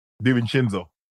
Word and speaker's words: DiVincenzo: Hey, yep. Devchenko? – DiVincenzo. – Oh, did I say it wrong? – Yeah, DiVincenzo.